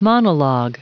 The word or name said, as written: monolog